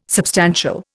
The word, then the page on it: substantial: Indian